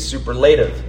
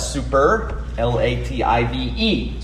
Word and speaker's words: superlative: If you were me a couple years ago, you would have said superlative because I don't know how to pronounce things. But that's the wrong emphasis, of course, on that syllable. It's not superlative, but it helps you spell it. Super, L-A-T-I-V-E, superlative. That's the correct way to say it.